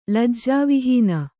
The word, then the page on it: ಲಜ್ಜಾವಿಹೀನೆ: Kannada